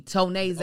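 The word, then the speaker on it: Tonéx: Tonay's a guy. – Oh, it's pronounced Tonay.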